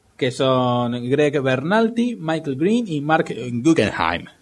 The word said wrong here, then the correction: Berlanti: Greg Berlanti. No, Berlanti.